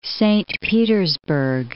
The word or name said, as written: Saint Petersburg